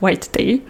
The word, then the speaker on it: White Day: und es wird tatsächlich Englisch ausgesprochen. Also hier heißt White Day, heißt White Day.